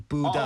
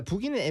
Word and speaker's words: Buddha: 여러분, not 부기, Buddha. – 어, 죄송합니다. 네. 발음 잘해주세요. – 어, 근데 부기는 알 거예요. 부기는 MZ잖아요.